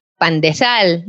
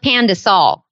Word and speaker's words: pandesal: You can have one, but say it right. Pandesal, not pandesal.